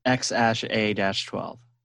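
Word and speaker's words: X Æ A-12: So how you pronounce the name is X and then the AE is pronounced Ash. And then A-12 is just A-12. – X-A-12.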